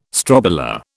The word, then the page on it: strobila: British English